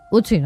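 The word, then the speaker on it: Ötzi: Otzi, creo que se llamaba, un nombre muy, muy noruego. – Utzi, ¿no? – Utzi, pues sí, Utzi. No sé, lo pronuncias tú mejor que yo. – Utzi, vale.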